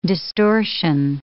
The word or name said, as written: distortion